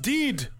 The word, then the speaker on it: Dead: Your name is Dead, huh? – Okay. It's pronounced Deed.